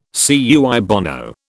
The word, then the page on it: cui bono: British English